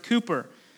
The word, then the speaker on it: Cowper: It's spelled Cowper, but it's pronounced Cooper.